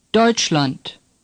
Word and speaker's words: Deutschland: Say Deutschland. – EU is pronounced like oi in boy.